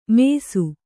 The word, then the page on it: ಮೇಸು: mēsu